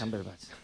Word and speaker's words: Cumberbatch: Cumberbatch, efectivamente.